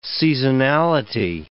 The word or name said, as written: seasonality